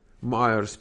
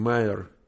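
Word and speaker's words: Meyer: Майерс пишется вот так. Он даже не Майерс, он Мэйер. Ага, вот так. Капи. Сейчас я вам сброшу сюда. Да, он оказывается не Мэйерс, а просто Мэйер.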